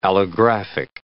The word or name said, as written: allographic